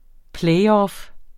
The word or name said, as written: playoff